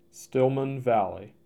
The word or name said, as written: Stillman Valley